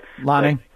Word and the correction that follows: Laine: Yeah, Laine.